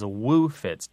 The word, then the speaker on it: woofits: It is woe fits. Sometimes it's spelled and said as woo fits, W-O-O-F-I-T-S, and it just means a hangover.